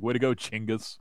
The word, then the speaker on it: Chinggis: they actually pronounce that uh, they don't say Genghis, they they say Chinggis.